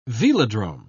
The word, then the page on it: velodrome: British English